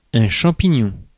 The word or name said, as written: champignon